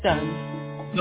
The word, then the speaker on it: Dunn: And your last name is spelled Doom, pronounced Doom? – Dunn. – Dunn. I apologize, Dunn.